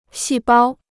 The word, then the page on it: 细胞: xì bāo